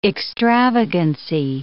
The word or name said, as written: extravagancy